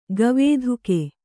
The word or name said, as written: ಗವೇಧುಕೆ